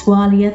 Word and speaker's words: Walia: My name is spelled B-E-L-Y-N-D-E-R. And it's Walia, W-A-L-I-A.